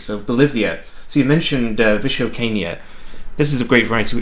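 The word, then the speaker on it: Vischoqueña: It took me several Bolivian producers going, no, Vichocaina. Okay, Vichocaina. It's hard to pronounce, but it is, yeah, it is a very unique grape variety.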